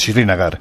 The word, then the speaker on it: Srinagar: Es que la pronunciación de la capital es Srinagar. Muy difícil. Es Srinagar.